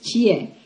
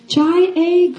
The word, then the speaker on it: Jie: You see, I, I've been going by my Korean name until that point, which is Jie, Kim Jie. It means to know love. It's a pretty name. But the reason why I asked for an American name is so that people could actually pronounce my name right. Right? Because who, who of, of you have gone through that trouble of like, Jai A. Kim? You know, like, like is that?